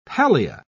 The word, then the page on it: pallia: British English